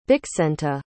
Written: bixenta

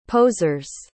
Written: posers

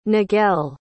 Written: niguel

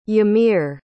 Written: ymir

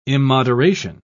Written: immoderation

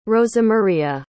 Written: rosamaria

